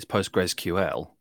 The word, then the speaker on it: PostgreSQL: You did get that title wrong. It's PostgresQL. – Most people don't normally pronounce the QL that I've heard. So I guess that's probably a potato, potato, tomato, tomato.